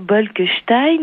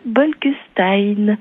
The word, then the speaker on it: Bolkestein: Et la deuxième chose, je vous en supplie, j'ai fait 4 mois de néerlandais, mais arrêtez de dire Bolkestein ou Bolkenstein. Ça ne se dit pas comme ça, le S en néerlandais, comme en français, se prononce S. Donc s'il vous plaît, par respect, dites Monsieur Bolkestein.